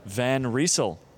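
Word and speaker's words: Van Rysel: Today's episode is brought to you by Decathlon and Decathlon's in-house performance cycling brand, Van Riesel, which I did check. That is how you pronounce it, despite the fact that Ronan thinks I'm wrong.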